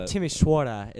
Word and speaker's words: Timișoara: Oh, Timișoara is what, yes.